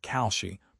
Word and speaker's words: Kalshi: It is the same story with prediction markets like Kalshi, pronounced K-A-L-Shi, which operate in a legal gray area because the Commodity Futures Trading Commission is too outdated to enforce actual rules.